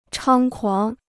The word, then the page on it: 猖狂: chāng kuáng